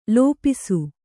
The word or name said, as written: ಲಿಪಿಸು